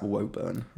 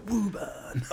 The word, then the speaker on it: Woburn: I would pronounce that Woburn. I always, yeah. It's always weird when... – W-O-B-U-R-N. Yeah. Woburn.